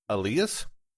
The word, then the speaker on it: Alias: All right, the first name is spelled as Alias, A-L-I-A-S, but is pronounced Elias. And the last name is V-A-L-A-M-I-N, Valamin.